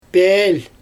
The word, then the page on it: peelJ: Xyzyl